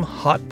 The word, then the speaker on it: Haute: Look for her products under the brand name Hot Bread. That's spelled H-A-U-T-E.